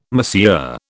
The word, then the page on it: Monsieur: British English